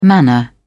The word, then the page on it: manner: British English